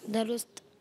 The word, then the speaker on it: درست: تو یہاں تو دال زبردہ ہے تو درست ہوا نا یہ.